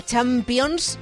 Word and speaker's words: Champions: Exacto, nos va a salvar Movistar Plus, porque además esta semana viene la Champions, muy bien pronunciado así con S, para compartir con todos nuestros oyentes que van a disfrutar como nunca Movistar Plus con el Real Madrid-Leipzig.